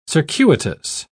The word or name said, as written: circuitous